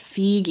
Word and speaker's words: Feig: It's Paul Feig? – Feig, yeah.